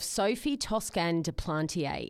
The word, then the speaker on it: Sophie Toscan du Plantier: It involves the murder of Sophie Toscan de Plantier in West Cork, Ireland.